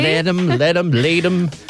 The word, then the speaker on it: Leedham: This morning, we are looking for Amanda Ledham of North Nowra. Amanda Ledham of North Nowra. It's spelt L-E-E-D-H-A-M. Hopefully I'm pronouncing it correctly. – Lead them.